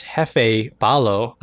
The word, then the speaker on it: Jefte Palo: Our artist today is, I believe it's pronounced Jefe Palo.